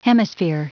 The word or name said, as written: hemisphere